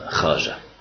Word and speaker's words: خواجة: إيه خاجة تكتب خواجة، تلفظ خاجة.